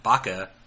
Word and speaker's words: baka: The Chinese characters for horse and deer together in Japanese are pronounced baka, meaning idiot.